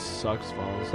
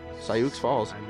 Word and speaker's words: Sioux Falls: Where's Sucks Falls? – That's where. That's how it's spelled. Is Sioux. Sioux Falls. Sioux.